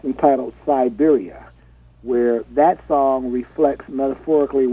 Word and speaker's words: Cyberia: Siberia spelled with a C. – Okay, got it. I love it. – Like C-Y-B-E-R-I-A.